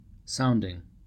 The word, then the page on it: sounding: American English